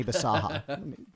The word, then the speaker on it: Bisaha: It rhymes with Mani Persona. – Yeah, and it's Johnny Bisaha. B-A. – And it's also... – B-I-S-A-H-A.